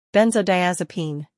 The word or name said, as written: Benzodiazepine